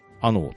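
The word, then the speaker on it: 穴太: で、このあの集というのが、漢字で書くと、穴、蔵の穴に、太い細いの太いと書いて、あのと読むんですが。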